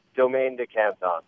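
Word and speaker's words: Domaine de Canton: How do you pronounce it? – Domaine du Canton.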